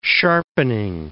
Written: sharpening

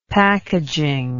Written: packaging

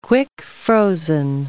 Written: quick-frozen

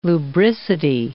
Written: lubricity

